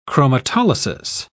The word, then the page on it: chromatolysis: British English